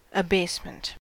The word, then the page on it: abasement: American English